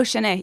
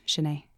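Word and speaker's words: shen eh: For for saying that's it, Shanae. – That's it. Oh, Shanae. Yeah, S H E N space E H, Shanae.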